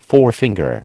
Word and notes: forefinger: American English

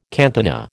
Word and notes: cantina: American English

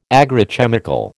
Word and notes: agrochemical: American English

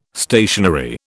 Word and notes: stationery: British English

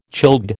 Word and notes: childbed: American English

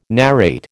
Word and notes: narrate: American English

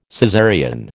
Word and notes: Caesarean: American English

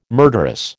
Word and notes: murderous: American English

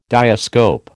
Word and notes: diascope: American English

